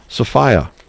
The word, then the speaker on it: Sophiea: Paul Sophia, that's how he pronounces it, S-O-P-H-I-E-A.